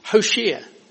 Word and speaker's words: Hoshea: That's not just my Sean Connery pronunciation of Hosea. It's Hoshea.